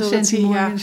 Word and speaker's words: centimorgans: En de centimorgans ja zeg ik het goed? – Ja, hè? de centimorgans ja.